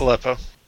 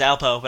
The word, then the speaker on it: Aleppo: Aleppo. – Yeah, thank you. I always want to pronounce... When I see it written, I always want to pronounce it as Alpo, but I'm like, that is not going to be...